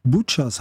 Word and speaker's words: Butcher's: Butcher's House aus der CD Whiskey and Bullets. Ich glaube, das klingt besser.